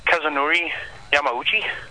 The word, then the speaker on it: Kazunori Yamauchi: Kazunori Yamauchi? Did I say that right? – Yeah, actually, you did far better than had we left that up to Scott.